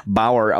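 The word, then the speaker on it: Baauer: Ich glaube, es wird sogar Bauer ausgesprochen, aber ja.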